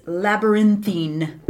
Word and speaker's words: labyrinthine: I'm going to totally mispronounce it. It's labyrinthine. Did I yeah. – say that right? – Yeah, sure.